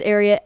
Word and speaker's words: Ariat: But it is Ariat after the horse secretariat.